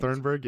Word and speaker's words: Thunberg: Thunberg, yeah.